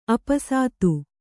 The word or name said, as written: ಆಪಸಾತು